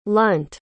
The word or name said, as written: lunt